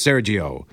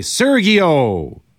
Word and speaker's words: Sergio: My name is Sergio. People say Sergio, like the Gee and McGee.